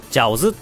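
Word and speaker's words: 饺子: 餃子っていうのは、ジャオズって読むんですよ。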